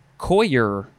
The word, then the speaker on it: coir: Next is coir. I think that's how you would say it. Coir. It is spelled C-O-I-R.